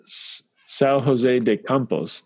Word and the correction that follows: São José dos Campos: Did I pronounce that correctly, Sasha? – Yes, it's understandable. Yeah, São José dos Campos, yes.